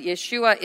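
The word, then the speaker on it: Yeshua (ישוע): But Yeshua is the original pronunciation, which means the Savior.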